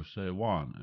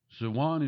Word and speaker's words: Sivan: At the airing of this episode, we are in the second day of the third month of Sivan, or Sewan as it is pronounced. And again, I'm speaking in terms of God time and how God created time and the seasons in which He works. Sewan is the third month of God's calendar.